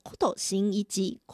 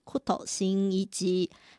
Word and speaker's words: 工藤新一: 工藤新一他的日文发音念作库头新一吉，库头新一吉。